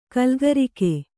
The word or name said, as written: ಕಲಿಕಾರಕ